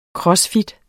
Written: funktionel

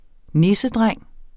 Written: nissedreng

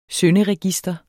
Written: synderegister

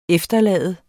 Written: EFTERLADES

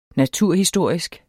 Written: naturhistorisk

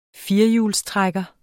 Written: firehjulstrækker

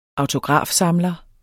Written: autografsamler

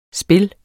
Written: spil

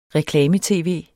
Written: reklame-tv

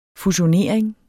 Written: fusionering

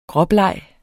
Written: gråbleg

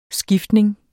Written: skiftning

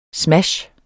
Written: smash

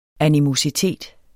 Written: animositet

